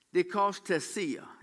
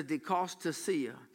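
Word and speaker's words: dichostasia: I'm going to just give you, they've got the pronunciation out there, dikostasia. It's D-I-C-H-O-S-T-A-S-I-A, if that helps you know why I have trouble pronouncing it. But the pronunciation out says dikostasia.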